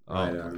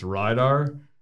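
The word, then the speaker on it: Reidar: So there's a contingent of us that say it's RIDAR, and there's a contingent that say it's RADAR. – So which contingent are you in? – I'm team RIDAR.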